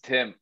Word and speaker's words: Thim: It's Tim. The H Tim. Is silent.